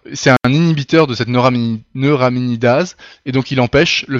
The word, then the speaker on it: neuraminidase: Par exemple, dans le cas du virus de la grippe, ce virus-là possède une neuraminase. C'est le N de H5N1, par exemple. Cette neuraminidase, elle lui sert à faire le... Attends, c'est dur à prononcer en plus. Neuraminidase, d'accord.